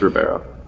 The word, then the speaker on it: Ribeiro: I feel like I'm pronouncing that wrong. I think it's Ribeiro. – I think it's Ribeiro. I'm pretty sure it's Ribeiro.